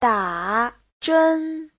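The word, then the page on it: dazhen: da3zhen1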